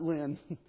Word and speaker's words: Lynn: Rhymes with my last name. Sin. That's right. Not Lynn.